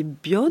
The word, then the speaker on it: Bion: Oui, parce que vous évoquiez Wilfred Bion, vous prononcez Bion. – Oui.